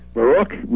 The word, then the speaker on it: Baruch: Baruch, meaning blessed in Hebrew, is how the family pronounced the the name many years ago, but the family converted to Protestantism, and then I converted further to Catholicism, and we pronounce it Baruch.